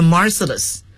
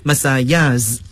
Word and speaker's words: Marseillaise: 这是著名的马赛曲的作者，大家注意这个马赛曲啊，它的英语读法嘛 ,The Marseillais。当然了我们也可以采用它原始的这个法语的发音 ,Marseillaise, 都可以啊，马赛曲。